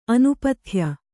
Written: ಅನಪತ್ಯೆ

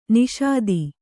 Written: ನಿಷಾದಿ

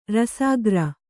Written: ರಸಾಗ್ರ

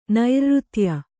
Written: ನೈರ್ಋತ್ಯ

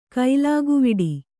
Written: ಕೈಲಾಗುವಿಡಿ

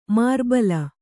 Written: ಮಾರಿಬಲೆ

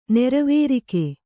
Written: ನೆರವೇರಿಕೆ

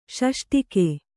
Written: ಷಷ್ಟಿಕ

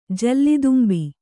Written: ಜಲ್ಲಿದುಂಬಿ

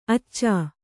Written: ಆಚ

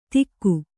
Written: ತಿಕ್ಕು

